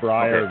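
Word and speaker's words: Brier: Well, so one of the questions was, with the when watching the Olympics or when watching the, is it Breyer Cup? Am I saying that right? – Yeah, so it's the Tim Hortons Breyer. So yep. – The term Breyer okay.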